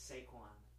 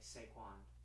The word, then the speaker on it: Saquon: I feel like it's Saquon. Like, like S-A-Y, but it's like it's not a Y. – But there isn't a Y. – I know, but I'm saying it's still pronounced like Saquon.